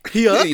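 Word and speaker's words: Huyck: Hyuk. It's pronounced like that.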